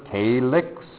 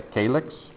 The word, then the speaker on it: calyx: We call this a minor calyx. That's how we pronounce it. That's a calyx.